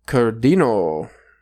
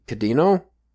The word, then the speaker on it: Kirino: It's hard for me to say the R's in Japanese because it's between a L and a D, but sometimes it's still pronounced R, but like, for instance, Kirino, I think it's actually Kadino, or like, it's hard to say.